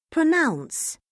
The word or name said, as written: pronounce